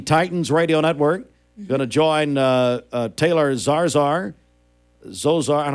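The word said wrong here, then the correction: Zarzour: Andy Morris sent us that pronunciation earlier, Zarzor. – Zarzor.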